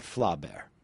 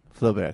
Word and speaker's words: Flaubert: In Flaubert, in Madame Bovary. – I think you'll find it's I think you'll find it's Flaubert. – Flaubert. – Not Flaubert. – Flaubert, excuse me.